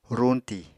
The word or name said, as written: ruñtì